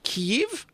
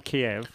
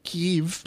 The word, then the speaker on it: Kyiv: Now, Eurovision will be in, apparently, in Kyiv. I think that's how you say it. – Not Kiev. – No. Kyiv.